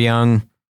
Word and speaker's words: Jung: who's a huge purveyor and fan of Carl Jung. You know, you see it written, it's J-U-N-G, but it's pronounced Jung.